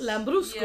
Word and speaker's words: Lambrusco: Lambrusco. – Yeah. – Rhymes with Prosecco. Kind of. Little bit.